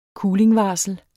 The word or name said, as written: kulingvarsel